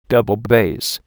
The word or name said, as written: double bass